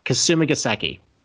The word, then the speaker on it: Kasumigaseki: All right, Kasumigaseki.